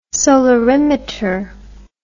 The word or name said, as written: solarimeter